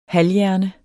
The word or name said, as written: halvhjerne